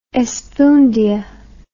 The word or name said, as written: espundia